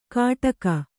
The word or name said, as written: ಕಾಟಕ